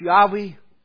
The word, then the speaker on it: Yahweh: Some say his name should be pronounced Yahweh.